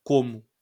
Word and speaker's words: Como: Como é que se escreve? – Como. Como. É tipo, lago de como, lago do porquê. É tipo, exatamente a mesma coisa. Lago de como, lago de porquê.